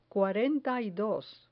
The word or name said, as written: cuarenta y dos